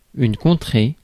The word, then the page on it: region: [ˈɹiː.dʒən]; /ˈɹiːdʒn̩/